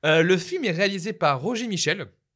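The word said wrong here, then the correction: Michell: Alors, je pense que je prononce très mal. Je pense que c'est Roger Mitchell.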